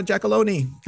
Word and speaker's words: Giacalone: Please help me welcome, from Strong Island, Cara Giacalone.